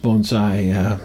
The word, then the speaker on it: bonsai: She said I pronounced bonsai correctly.